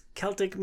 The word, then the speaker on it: Celtic: The theme is Arthurian legends meets Celtic mythology. Am I saying Celtic right? – Yeah. – Yes. – Thank goodness. – Not Boston Celtics. Yeah. Celtic is actually how it's said.